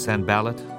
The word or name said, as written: Sanballat